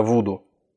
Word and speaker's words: Вуду: Это вот те зомби, которые связаны с э, религией Вуду или Вуду, наверное, Вуду. С религией Вуду, вот, и будем ориентироваться на Продиджи. Как они произносят, так и мы будем произносить.